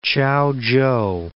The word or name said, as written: Chaochow